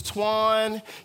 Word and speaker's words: Tquan: If we were meeting me for the first time, I would say, Hello, my name is Tquan.